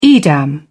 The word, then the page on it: edam: British English